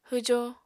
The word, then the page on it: hwjo: Apatani